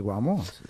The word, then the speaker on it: Guamo: Entonces, ¿qué Bésame, el Guamo? Se dice así. – Chao, Ana, chao. – Sí, claro, el Guamo.